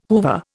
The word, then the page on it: Hoover: Indian